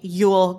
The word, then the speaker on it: Yule: Nevermore written by Kem Shumway and Demons written by Justine Yule Gilmer. And yes, that is the correct pronunciation of her name. Yule, as in Yul Brynner, the bald guy in The King and I.